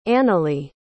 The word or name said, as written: analeigh